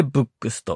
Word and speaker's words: VOOX: 次に 1 回の長さを 10 分くらいにというのは、ブックスという最近始まった音声メディア、アプリに影響を受けたという面があります。ブックスは VOOX でブックスと読みます。